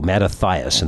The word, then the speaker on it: Mattathias: Led by Mattathias, Mattathias in the Greek pronunciation, a family known as the Hasmoneans struck back.